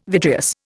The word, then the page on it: vitreous: Indian